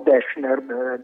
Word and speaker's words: Deschner: di Karl-Heinz Gessner, si dice, lo pronuncio bene perché ho chiesto, si dice dolce o si dice duro? No, Deschner, dolce,